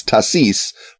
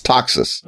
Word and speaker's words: Taxis: the Countess of Taxis, or is it Taxis? – It's since she was probably speaking French, it's Tassis, but okay. Since it's a German title, it's Taxis. – Okay.